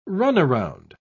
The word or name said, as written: runaround